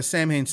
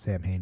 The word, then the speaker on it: Samhain: Not the Samhain stuff, specifically Danzig, that first album. Maybe two. – It's not supposed to be pronounced Samhain, by the way. – Samhain, I know.